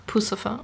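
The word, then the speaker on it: Puscifer: Genau, zum Beispiel Maynard James Keenan, der auch bei Perfect Circle oder Pussifer, spricht man das aus? – Also ich sag Pussifer.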